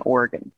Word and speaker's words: Oregon: Oregon. It's pronounced Oregon. I know it's spelled funny, but Oregon, just like a, you know, piano or your liver, same thing.